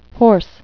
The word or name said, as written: hoarse